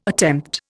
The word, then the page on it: attempt: Indian